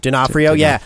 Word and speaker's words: D'Onofrio: D'Onofrio was probably like he stole the show in my opinion.